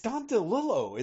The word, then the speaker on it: DeLillo: No, it's Don. Yeah, it's Don DeLillo. It's not.